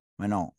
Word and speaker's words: Manon: And I actually remember, I don't know if I'm pronouncing her name correctly, Rayom. – Menon. – Yeah. Manon Rayon.